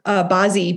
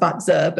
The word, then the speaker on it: Bazi: The calculator is called Bazi, B-A-Z-I. Mm-hmm. It's actually pronounced Batza, but it's okay.